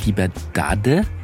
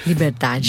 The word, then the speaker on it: Liberdade: In Liberdade, so würde ich es als Deutscher aussprechen, aber du sprichst es anders aus. – Liberdade.